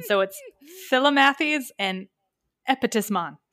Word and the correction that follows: Epistemon: Epistemon, there it is. And guess which one is the smart one? – Epistemon.